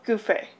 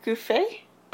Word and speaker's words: Gouffet: Tracking these murders is master detective Paul Gouffé. – Sorry, what? – Paul Gouffé? – Okay. Okay. No, I did hear you right. It's just his name sounds like Paul Goofy, and so I wanted to double check there. – Yeah, it's spelled G-O-U-F-F-E-T.